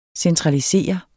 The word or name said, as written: centralisere